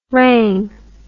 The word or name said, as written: wrang